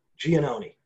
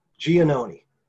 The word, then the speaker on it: Giannone: Giannone.